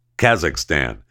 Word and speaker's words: Kazakhstan: Why can't I pronounce their stupid name? Kazakhstan. Kazakhstan, for those of you listening to Chewing the Fat in Kazakhstan, I apologize. I know it's Kazakhstan. It's me that has an issue every time I see the word. I want to pronounce it wrong. It's Kazakhstan. I get it.